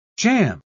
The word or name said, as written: Jamb